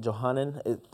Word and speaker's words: Johanan: You know, when he's told by, is it Johanan? That's the way I'm pronouncing it. – Sounds about right.